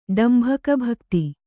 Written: ಡಂಭಕಭಕ್ತಿ

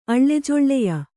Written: ಅಳ್ಳೆಜೊಳ್ಳೆಯ

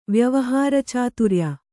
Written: ವ್ಯವಹಾರಚಾತುರ್ಯ

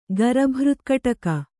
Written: ಗರಭೃತ್ಕಟಕ

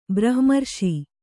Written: ಬ್ರಹ್ಮರ್ಷಿ

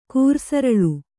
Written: ಕೂರ್ಸರಲ್

